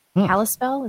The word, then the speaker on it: Kalispell: Kalispell, is that how you say it? – Yep.